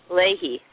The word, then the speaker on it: Leahy: I know Senator Patrick Leahy, I think that's how you pronounce it. He's from Vermont. What is it? – Leahy, thank you.